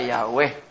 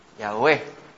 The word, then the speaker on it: Yahweh: Pero los estudiosos de estos detalles pues están concertados en que el más cercano era Yahweh, o de ahí es que viene Jehová, Yahweh.